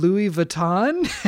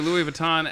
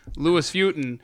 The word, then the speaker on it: Louis Vuitton: Okay, so if you're listening right now and someone in your life refers to Louis Vuitton as Lewis Futen, you'll know that they called Ricky Goins during that week back in...